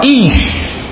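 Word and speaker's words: ish (איש): A mulherada já fala ish. Ish. Homem em hebraico é ish. E fogo em hebraico é esh. É próximo ou não? Ish e esh.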